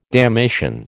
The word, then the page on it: damnation: American English